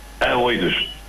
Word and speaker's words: Aioidos: Os Aiodos. Os Aiodos. Como é que isto se pronuncia? – Aioidos. – É Oidos, ok.